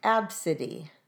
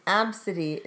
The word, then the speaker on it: Abcde: One that was brought to our attention was Absidy. And Absidy is spelled A-B-C-D-E. Absidy. That's it.